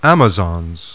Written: AMAZONS

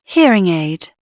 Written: hear

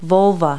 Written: volva

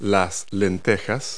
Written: lentils

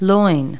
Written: loin

